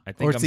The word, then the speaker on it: Orczy: Yeah, Orczy is the pronunciation I found too, so we're good.